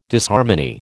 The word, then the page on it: Disharmony: American English